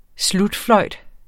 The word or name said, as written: slutfløjt